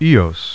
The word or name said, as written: Eos